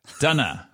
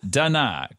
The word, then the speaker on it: Dunna: Say my fucking name. Dunna. Pronounced Dunna. Kind of like Donna, but with more of an than an on.